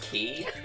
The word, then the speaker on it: quay: Okay, which so quay apparently it's pronounced key. Okay.